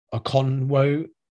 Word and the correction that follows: Okonkwo: Okonkwo? – Yeah, sorry, that was a terrible pronunciation, wasn't it? Okonkwo, I should say, are going to step up, or whether they can find a sort of number three.